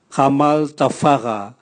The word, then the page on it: gamal tavwaga: Raga